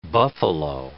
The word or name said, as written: buffalo